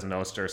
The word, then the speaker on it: Nostr: One final note on pronunciation. I've been saying Nostr for a while. You can tell I'm going back and forth because Alex says Nostr, so I started saying Nostr during the interview because I'm easily influenced.